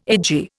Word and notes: edgy: Indian

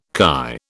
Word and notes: KY: British English

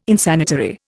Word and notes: Insanitary: Indian

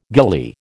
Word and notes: ghillie: American English

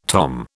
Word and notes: tom: British English

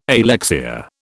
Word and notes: alexia: British English

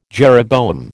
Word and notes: jeroboam: American English

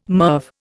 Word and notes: MIRV: Indian

